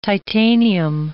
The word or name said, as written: titanium